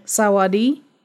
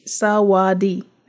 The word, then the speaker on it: Sawadi: Say that again. – Sawadi. S-A-W-A-D. Sawadi. That's how you say it.